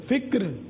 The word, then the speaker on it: فکر: فرمانے باپا، علم، ذکر، فکر، شکر، صبر، یہ تلفظات درست ہیں.